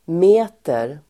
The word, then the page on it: meter: [m'e:ter]